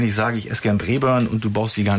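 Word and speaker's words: Braeburn: Ja, Brebern ist schon richtig ausgesprochen.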